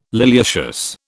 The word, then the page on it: liliaceous: British English